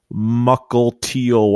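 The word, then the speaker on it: Mukilteo: Have fun in Muckle Teal, Washington. Did I say it right? – Yeah, you did.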